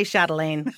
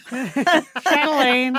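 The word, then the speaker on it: Châtelaine: Am I pronouncing that correctly? – You could just say Chatelain. – Chatelain. – Chatelain. – Well, so you're Canadian. That's the official pronunciation? – Yes.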